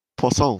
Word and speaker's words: Poisson: I'm assuming that's how you say it in French. If you go, it always sounds French. So, Poisson. – It sounds a bit like you're calling him André Fish there. – That's what I thought. – Why? What's fish in French? – Poisson. – Poisson. – How do you spell that? – P-O-I-S-S-O-N. – Well, that's how it's spelled.